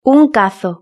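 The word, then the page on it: un cazo: Spanish (Spain)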